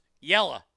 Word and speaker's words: yellow: Yellow in the South is pronounced yella. Window in the South is pronounced winda.